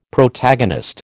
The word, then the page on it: Protagonist: American English